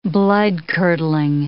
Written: bloodcurdling

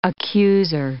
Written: accuser

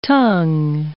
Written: tongue

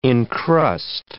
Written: incrust

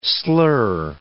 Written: slur

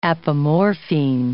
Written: apomorphine

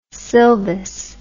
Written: sylvis

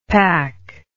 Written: pac

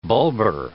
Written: bulbar

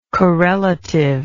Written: corelatively

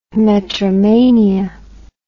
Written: metromania

